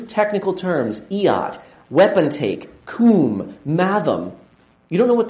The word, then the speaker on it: cwm: Coom, the deeping coom, it's Celtic for deep valley. It shows up, it's spelled C-W-M in Welsh, but it's in parts of Mount Everest are called the coom and so forth, where you would stop.